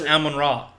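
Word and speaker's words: Amon-Ra: No, it's pronounced Amon Ra. – Amon Ra? – Like the Egyptian.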